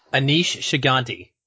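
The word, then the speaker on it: Aneesh Chaganty: And, you know, how you pronounce his name, I don't want to butcher it. – Anish Shigante. – Yes, Anish Shigante.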